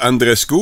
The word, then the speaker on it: Andreescu: Tennis, la Canadienne Bianca Andreescu, oui, c'est comme ça que ça se prononce, Jean-Michel, a perdu son match de troisième tour contre la Tunisienne Hans Jabeur hier à Montréal.